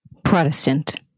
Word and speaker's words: Protestant: How do you say that? – Protestant. Like a Prada bag with a stint.